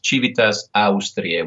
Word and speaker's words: Civitas Austriae: Well, actually, Cividale is a name that was given to it by the Longobards was Civitas Ostrae, which basically meant Eastern City. – I'm going to say it again. It's Civitas Austriae, which is Latin.